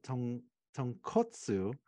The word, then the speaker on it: tonkotsu: But yeah, so oh, the other part I want to mention before I conclude about my trip there was you want to watch out the pronunciation of tonkatsu and what is it? Tonkotsu, which is a pork-based ramen broth.